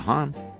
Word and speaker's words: Hahn: That's right, Jessica Hahn. Rhymes with Fawn.